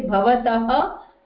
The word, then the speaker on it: भवतः: So you have to say bhavatah nama kim.